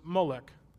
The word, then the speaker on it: Molech: And one of the gods that they worshipped was Molech. A couple different spellings on it. That's how it's said.